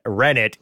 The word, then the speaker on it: rennet: And we learned from Wintermute that the T in rennet is pronounced. So it's not like a silent T.